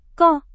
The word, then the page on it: kok: Indonesian